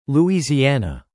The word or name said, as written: Louisiana